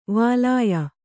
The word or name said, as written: ವಾಲಾಯ